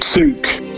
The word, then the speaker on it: Tzuke: Judy, how are you pronouncing this one? Judy Tunsky? – Souk. – Souk, right, there you go.